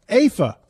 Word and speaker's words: UEFA: Also, UEFA, I think that's how you pronounce it for you soccer folks. Say again? – AFA.